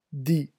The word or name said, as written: dit